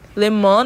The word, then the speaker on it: Le Mans: Les Mans, Le Mans, na verdade, né? Se é francês, vou ter que pronunciar certo, na França.